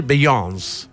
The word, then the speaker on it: Beyoncé: A mí me gustaba decir Beyoncé, pero es Beyoncé con acento en la E. Pero bueno, no es Beyoncé, sino que es Beyoncé.